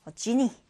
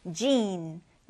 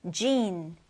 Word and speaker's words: gene: 자, 이 단어 여러분 발음 주의하세요. 지니, 램프의 요정 지니가 아니라 gene, gene 이렇게 읽어주는데요.